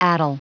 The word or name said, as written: addle